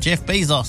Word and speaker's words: Bezos: Yep, Jeff Bezos.